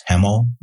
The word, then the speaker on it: Hemal: He is Hemal Badiani.